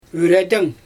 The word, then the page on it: yredəŋ: Xyzyl